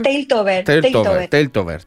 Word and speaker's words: Teiltober: ¿Cómo lo pronuncio? ¿Teiltober? – Sí, Teiltober.